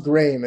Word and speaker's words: Graeme: So it's pronounced Graeme. It's like frame with a G.